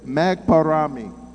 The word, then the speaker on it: magparami: The second thing he asks is magparami. Did I say that correctly? Magparami. – Oh, yeah, that's Tagalog.